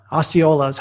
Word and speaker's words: Osceola: Osceolas. How do you pronounce that word? Osceola. And everyone in Indiana is now happy I got it right.